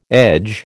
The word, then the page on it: edge: American English